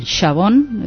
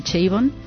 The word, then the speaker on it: Chabon: Michael Chabon, se pronuncia a la francesa, o a lo mejor en Estados Unidos prefieren decir Chabon.